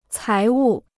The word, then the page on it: 财物: cái wù